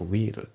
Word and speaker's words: wheel: この wheel っていうね、これ日本語だとホイールっていう風に言うと思うんだけども、これ英語の発音だと wheel っていう発音なんですよね。